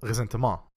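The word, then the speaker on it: ressentiment: Sorry, resharing, comrade, called resentment, right? Yeah. And this idea that... – Pronounce the French way. – Pronounce the French way, you have to, because it's not exactly like resenting someone, right?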